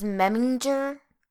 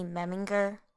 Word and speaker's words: Memminger: I always thought that it was Memminger, but it's apparently Memminger. – Apparently. Yeah, I think every time that I saw the name and I read it to myself, I pronounced it differently each time. Sometimes I would say it with the soft G. Other times I would say it with the hard G.